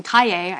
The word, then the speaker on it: Kaye: And it turns out David Welch, who put like $250,000 into this thing, is extremely unhappy with Brian Kaye, I know that's how that's pronounced,